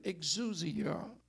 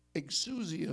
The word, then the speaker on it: exousia: The third power here comes from the Greek word exousia or exousia. But exousia is the more preferred pronunciation.